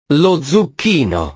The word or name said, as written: lo zucchino